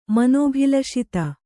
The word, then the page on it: ಮನೋಭಿಲಷಿತ: Kannada